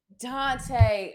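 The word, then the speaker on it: Daunte: W-R-I-T. – No, you just said Danute. His name is Dante Wright. – Dante.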